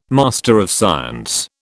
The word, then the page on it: MSC: British English